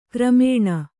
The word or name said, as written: ಕ್ರಮಣ